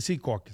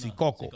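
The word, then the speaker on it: Secaucus: Union City, West New York, North Bergen, eh, o no se dice Sicoco, se dice Sicoco.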